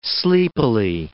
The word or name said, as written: sleepily